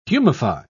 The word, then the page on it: humify: British English